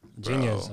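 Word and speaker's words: Jeen-Yuhs: Yeah, but the way it's spelled, JEENYUHS. – Yeah, it's supposed to be like genius. Yeah, like plural.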